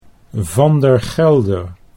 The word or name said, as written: Van der Gelder